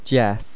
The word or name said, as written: deas